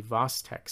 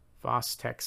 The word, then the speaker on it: Chvostek: So this is going to be known as the Vostek sign. Vostek sign, which is spelled C-H-V-O-S-T-E-K sign.